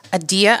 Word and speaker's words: Adia: You can follow me on Instagram at Adia _. That's A-D-I-A.